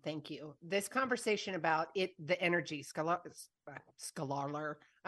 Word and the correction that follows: Scalar: I'm like blurting it out again. Say it out loud. – Scalar energy. – Scalar, because I was going to mispronounce it again.